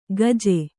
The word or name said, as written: ಗಜ್ಜೆ